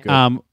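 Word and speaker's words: Goode: Is it Good? – Yeah. – I'm assuming the E is silent. Yeah. – Matthew Good.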